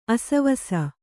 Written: ಆಶ್ವಾಸ

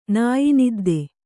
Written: ನಾಯಿನಿದ್ದೆ

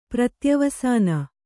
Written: ಪ್ರತ್ಯವಸಾನ

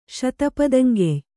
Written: ಶತಪದಂಗೆಯ್

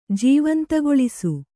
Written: ಜೀವಂತಗೊಳಿಸು

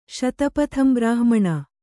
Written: ಶತಪಥಂಬ್ರಾಹ್ಮಣ

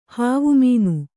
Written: ಹವಾಮಾನ

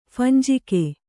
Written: ಫಂಜಿಕೆ

